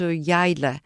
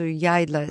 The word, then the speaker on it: Yayla: Town along the shore, you can find minibuses going to Yayla. Please remember that word. You want to go to Yayla. – How do you spell that? – Y-A-Y-L-A.